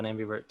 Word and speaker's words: ambivert: Yeah, it's interesting you bring up ambivert. I think I didn't know this term before you mentioned it, but I think I've learned that I'm a little bit of an ambivert too.